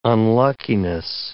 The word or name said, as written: unluckiness